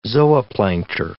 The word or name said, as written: zooplankter